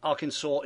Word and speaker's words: Arkansas: The last S in Arkansas is silent, Mike, okay?